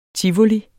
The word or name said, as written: TIVOLI